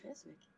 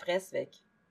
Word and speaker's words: Fresvik: Eller Fresvik, jeg er ikke sikker på hvordan det uttales. – Fresvik. – Mm, ja. Eh, vi er, vi er enstemmige om at det er Fresvik.